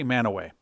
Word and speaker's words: Mantua: There's also a place called Manaway, and they spell that M-A-N-T-U-A. So it looks like Mantua, but they say Manaway.